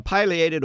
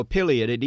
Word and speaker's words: pileated: The pileated or pileated, either pronunciation is okay. It's like tomato, tomato.